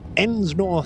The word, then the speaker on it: Ensnor: That individual, Ensnor Thane. – Nor Thane? – Ensnor Thane. – What? – Ensnor? – How do you spell it? – E-N-S-N-O-R, Ensnor Thane.